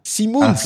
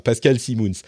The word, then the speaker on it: Simoens: Bonjour Patrick, presque, c'est Simoens en fait. – Simoens. Damned, c'est pour ça, effectivement. Tu sais, moi, je connais le nom de famille, mais j'ai la prononciation à la française.